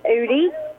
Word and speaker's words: Eudy: Lisa Udy. – Lisa Udy? – Uh-huh. – All right. – E-U-D-Y.